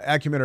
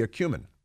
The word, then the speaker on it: acumen: Some prefer acumen to acumen. It's like accuse, A C C, accuse, acumen, A C C U, uh, acumen or accuse. So many, you know, the more common pronunciation is acumen but some say it should be a cumin.